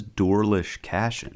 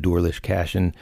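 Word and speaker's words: Doarlish Cashen: Like I said, this is Dorlish Cashin. – Yeah. That's how you say it in Manx basically. So, and if you're from Isle of Man, you call it Dorlish Cashin.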